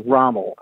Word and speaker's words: Rommel: And my name is Rommel, and that's spelled R-O-M-M-E-L.